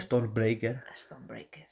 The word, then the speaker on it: Stormbreaker: Stormbreaker.